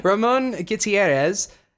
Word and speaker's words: Ramon Gutierrez: Ramon Gutierrez, hopefully I said that right, from Andromida.